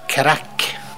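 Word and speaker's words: craic: Og da er det jo verdt å merke seg at irrene har et eget ord for det med god stemning, og det uttales crack.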